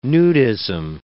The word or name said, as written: nudism